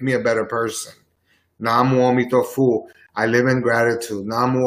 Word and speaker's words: Amitabha: His name is Amitabha. Amitabha. That's how they pronounce it.